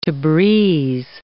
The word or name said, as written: Tabriz